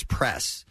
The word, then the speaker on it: Press: So, you know, my shortened name is Press. But it's two syllables away. She says it's Prayus. – Right. – Prayus is basically what she's saying.